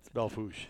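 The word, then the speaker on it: Belle Fourche: He just left and ran to Belfourche. Is that how you say it? – Belfourche.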